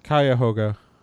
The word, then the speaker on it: Cuyahoga: It's the Cuyahoga State Park. I'm probably pronouncing that wrong. Cuyahoga? – No, it's pronounced Cuyahoga. – Cuyahoga. Okay. – At least that's how I've always heard it.